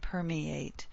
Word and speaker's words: permeate: So permit, permeate. Two syllables in permit. Three in permeate. So permeated. We need that extra syllable. Four syllables in permeated.